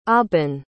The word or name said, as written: Aaban